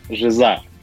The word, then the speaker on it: Жиза: Мы вчера с коллегами пытались а, понять, куда правильно ставить ударение. Ну, со сошлись на том, что на первый слог, на «и». – Все любят делать так, но мы почему-то изначально назвали канал «Жиза».